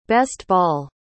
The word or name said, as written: best-ball